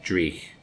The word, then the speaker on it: dreich: Now, this beer, I think, is particularly well-suited to a fairly dreich day such as today. It's been cold and rainy. – Did you say fairly what day? – Dreich.